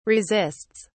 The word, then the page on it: resists: British English